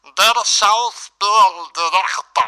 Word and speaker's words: Der Schauspieldirektor: Bueno, la pronunciación es un poco rara, pero es Der Schauspieldirektor.